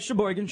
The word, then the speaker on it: Sheboygan: You know, you think about, Usinger and brats out of, Kohler and out of Sheboygan, which... – Right. – What? How am I doing? Did I pronounce that right? – No, you're right. Sheboygan. Sheboygan, Wisconsin. That's actually where...